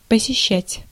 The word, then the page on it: посеща́ть: /pəsʲɪˈɕːætʲ/